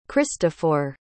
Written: cristofor

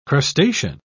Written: crustacean

